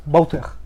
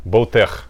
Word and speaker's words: Bowtech: Правильно или Bowtech? Как правильно говорится? – Bowtech. – Bowtech, правильно. Вот.